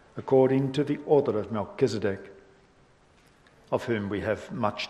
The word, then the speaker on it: Melchizedek: I noticed, by the way, that in this Bible, pulpit Bible, and I guess it's New King James, tells us how we ought more appropriately to be pronouncing Melchizedek's name, which is Melchizedek. And that's indicated here in the translation, so maybe I should pronounce it that way too.